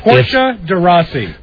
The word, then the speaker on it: Portia de Rossi: It's Portia de Rossi.